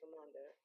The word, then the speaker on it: Ramonda: Ramonda. – Okay. Kind of like Zamunda.